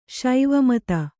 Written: ಶಿವಮತ

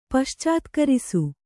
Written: ಪಶ್ಚಾತ್ಕರಿಸು

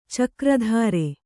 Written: ಚಕ್ರಧರ

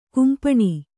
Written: ಖಂಪಣ

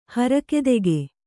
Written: ಹರಕೆದೆಗೆ

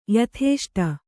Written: ಯಥೇಷ್ಟ

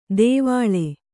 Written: ತೆವಳೆ

